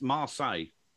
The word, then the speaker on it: Marseille: Don't say it like that. It's Marseille. It's Marseille, and it's the white one with the three Adidas large logos on the, coming over the shoulder.